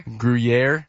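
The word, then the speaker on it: Gruyère: Gruyere. Gruyere cheese. – Look at me sounding old. Gruyere? – Gruyere. – I can't say it. – And now you got me thinking I can't say it. Gruyere cheese.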